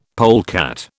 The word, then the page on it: polecat: British English